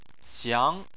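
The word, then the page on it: xiang: tone 2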